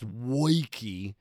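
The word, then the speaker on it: Woike: It's pronounced Wojcicki. He's woke with an I in the middle. He's I woke.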